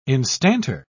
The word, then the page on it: instanter: British English